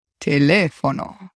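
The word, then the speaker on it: teléfono: In Spanish, it has four syllables. – Telefono. – Telefono. All of them are open syllables. They start with a consonant and end with a vowel sound.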